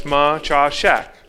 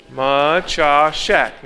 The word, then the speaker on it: Maciaszek: As you can tell, it is not an American name. It is a Polish name. So I am Polish, and I put it phonetically on there, so it's Maciaszek. So everybody say Maciaszek.